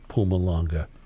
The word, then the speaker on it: Mpumalanga: Apparently, I'm supposed to pronounce it Mpumalanga. You don't pronounce the M, apparently.